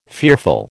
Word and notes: fearful: American English

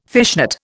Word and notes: fishnet: Indian

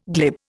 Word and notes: glib: Indian

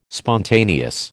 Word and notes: spontaneous: American English

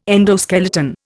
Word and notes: endoskeleton: Indian

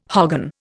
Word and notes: hogan: Indian

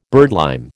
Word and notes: birdlime: American English

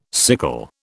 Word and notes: sickle: British English

